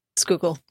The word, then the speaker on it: Schuylkill: No, he said the Schuylkill. No, Schuylkill. Say it one more time. – Schuylkill.